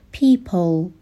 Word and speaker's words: people: نگیم people، صدای ای نیست، در واقع یک صدای مشابه صدای او هستش. people به این صورت باید عمیق تلفظ بشه.